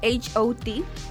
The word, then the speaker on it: H.O.T.: Bueno, empezamos con los grupos que han sido pues parte de SM Entertainment, que el primero fue HOT, que fue también de los primeritos que empezó a salir a la industria. Búscate mi bol se escribe hot. Hot. Pero es H.O.T.